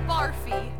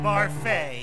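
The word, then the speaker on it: Barfée: Returning after last season's tragic setback, William Barfy. – It's pronounced Barfay. There's an accent ado.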